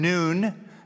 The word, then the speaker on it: Nun: Chapter 2, verse 1, now Joshua, the son of, I prefer saying Nun, that'd be the Hebrew pronunciation.